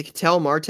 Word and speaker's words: Ketel Marte: So is it Ketel Marte? Am I saying that right? Yep.